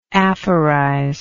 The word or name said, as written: aphorizer